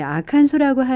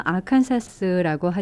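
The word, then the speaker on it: Arkansas: 예, 아칸소라고 하자는 의견과 아칸사스라고 하자는 의견이 갈렸는데요. 1880년대 주의회가 아칸소로 발음하기로 최종 결론을 내렸다고 합니다.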